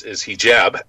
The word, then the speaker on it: hijab: hijabs? Is there anything to that? – Well, the pronunciation is hijab.